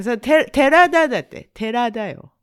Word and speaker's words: Terada: Could you pronounce Terada again? – Terada? – Sounds like you have to pronounce Terada properly, otherwise like a Turada.